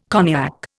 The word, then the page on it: cognac: Indian